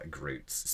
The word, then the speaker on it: gruits: I am Groots. Spelled G-R-U-I-T-S, but it's pronounced Groots.